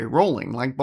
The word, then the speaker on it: Rowling: Rowling, like bowling.